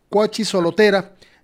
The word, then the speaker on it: Cuachisolotera: de agua potable a la localidad Rancho Cuachisolera, Cuachisolotera, si sí, lo pronuncié bien, Cuachisolotera, desde ahora,